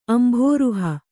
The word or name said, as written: ಅಂಬುರುಹ